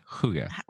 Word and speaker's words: hygge: I think is how it's pronounced. Hygge. – Okay. – The BBC says hygge. That's what the BBC. – Hygge. Yeah. Okay. Well, that's not how I was pronouncing it at all in my mind. – No, me neither. – Yeah, but I'm not Danish, so.